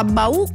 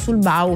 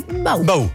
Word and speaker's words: Bau: Una arriva da Daniela che ci dice: si pronuncia Bau con l'accento sulla U, perché altrimenti si potrebbero risentire gli abitanti di Stoccareddo, che è un piccolo paese dell'Altopiano e frazione del comune di Gallio. Quindi grazie Daniela, noi eravamo andati sul Bau, perché diciamo che da un certo punto dell'Italia in giù si anticipa l'accento, invece è Bau.